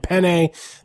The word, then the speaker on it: Penne: You just said Penne. – I know. I was waiting for it. I don't want you to do that. We're saying Penne. That's the official pronunciation of the Co-Main Event podcast. – Penne? – Of Jessica Penne's name. – Penne. – It's like the pasta.